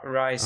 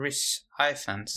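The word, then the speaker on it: Rhys Ifans: Eh, jag kan aldrig uttala hans namn. Rice, ja. – Riz Ifans.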